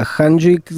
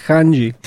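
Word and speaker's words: Changi: Je to letisko Singapur Hanjik, za ním je letisko... – Ako ešte raz? – Ono sa to vraj vyslovuje Hanji.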